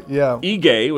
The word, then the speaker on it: Ige: You know, Governor Ige, which is how you pronounce his name,